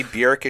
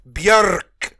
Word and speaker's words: Björk: And I was re-listening to that, and I'm sorry, it shouldn't be Bjork, it should be Bjork. You gotta roll your R.